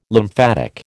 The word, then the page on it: lymphatic: American English